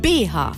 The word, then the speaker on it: BH: Also ich sage immer BH mit der Betonung auf das H. Ganz viele Leute sagen aber auch BH. Also betonen das B vorne.